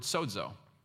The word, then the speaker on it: sozo: And so the word there is the Greek, the New Testament was written in Greek, it's the Greek word sozo. It's spelled S-O-Z-O, but it's kind of pronounced S-O-D-E-Z-O, I guess what.